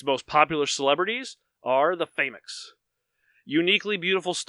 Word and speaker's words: Famoux: It's still pronounced famous. – No, it's not, because I went to her, by Cassandra Tate, I went to her website, and she has a little YouTube, a little video of where she's describing it, and she pronounces it Famix.